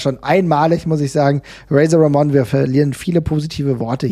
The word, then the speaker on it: Razor Ramon: dass er nicht wie ich es vielleicht sagen würde, Razor Ramon genannt wurde, sondern Razor Ramon. Also diese Aussprache, diese, ähm, ähm diese Aussprache in diesem Puerto ricanio style könnte man fast sagen,